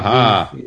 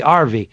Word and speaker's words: Harvey: Harvey. – It's British, so they pronounce Harvey RV.